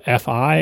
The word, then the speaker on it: Fi: The company name, or the product name, and I guess the company name as well, is spelled F-I, and I was pronouncing it Fee because that seems reasonable. But Lucy from the company that's spelled F-I tells us that it's pronounced like Fido or Wi-Fi. So it's Fi. Like Fee Fi Fo Fum, or F with the word I after it. Lucy says, many, many people go for Fee first.